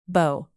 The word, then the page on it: bow: knot